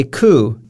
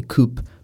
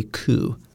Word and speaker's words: coup: We will say coup, and this word is actually a French word, so it doesn't follow English pronunciation, because normally we would probably say coup, but it is a word that's from France, so we say coup.